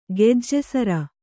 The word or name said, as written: ಗೆಜ್ಜೆಸರ